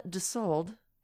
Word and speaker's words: Dusold: And one of those people is my guest today, and that is Amanda Dusold. – Hello. – Hello. And I said it correctly, right? – Yes. It's like Dusseldorf without the or.